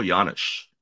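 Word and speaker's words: Janish: They have Paul Janisch, everyone's favorite soft J, everyone's favorite slick fielding red from early 2010s.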